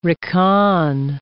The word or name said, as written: recon